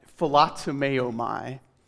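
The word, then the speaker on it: philotomeomai (φιλοτιμέομαι): It's pronounced something like philotomeomai, which is fun to say, so you can do that on your own sometime.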